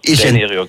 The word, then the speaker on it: Raineri: Ist das richtig, wie es ausgesprochen? – Heile, ja, das stimmt, ja. Raineri, genau.